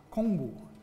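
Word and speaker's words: Kongbu (공부): in Korean is pronounced Kongbu, which actually goes back to the teachings of one of Confucius's main subsequent disciples, which refers to moral cultivation.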